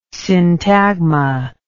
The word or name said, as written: syntagma